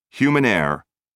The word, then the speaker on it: Humannaire: In fact, note, human heir, spelled HUMANNAIRE, rhymes with human rare.